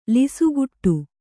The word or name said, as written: ಲಿಸುಗುಟ್ಟು